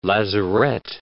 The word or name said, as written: lazaret